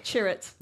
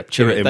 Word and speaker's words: Chirrut: Chirrut. – Yeah, Chirrut.